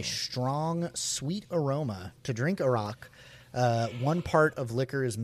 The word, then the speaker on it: Arak: So A-R-A-K. Sorry. Read that spelling again. – Yeah. Arak. – Arak. – Like the country. Iraq, which is right. How you're supposed to pronounce it, and people correct.